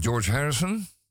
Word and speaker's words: George Harrison: En weet u overigens dat dit nummer, eh, gezongen door George Harrison, zeg ik dat goed?